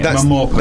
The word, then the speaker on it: MMORPG: Is that how you're saying it? Momorpaga. – That's the sort of official pronunciation of the acronym, yeah.